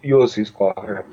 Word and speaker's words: Ulysses Klaue: But during Ultron, there's the scene where the Scarlet Witch, while still working for Ultron, does the part when they're fighting Ulysses Klaue, or however you say his name. And Ulysses Klaue.